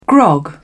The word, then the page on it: grog: British English